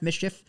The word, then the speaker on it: MSCHF: Is that how you pronounce it? M S C H F, Mischief? – Yeah.